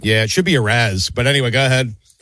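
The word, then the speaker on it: Arraez: Arias. – Yeah. I mean, Jesus Christ. But Luis, how do you say it again? – Arias.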